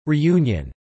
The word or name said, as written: reunion